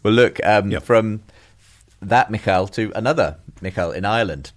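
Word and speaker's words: Mícheál Ó Siadhail: I think he pronounces it Michal. – Okay. – Michal O'Shiel.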